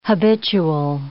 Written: habitual

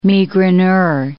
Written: migraineur

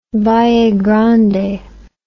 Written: Vallegrande

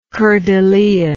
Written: coeur de lion

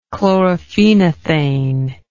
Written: chlorophenothane

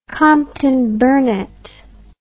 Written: comptonburnett

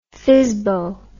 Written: fizzbo

